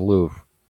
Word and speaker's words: Louvre: Yeah, the Louvre.